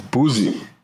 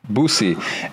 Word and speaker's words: Boosie: What is, has Boosie, I keep pronouncing it wrong. It's Boosie. Boosie. Is that right? – There you go. – Boosie.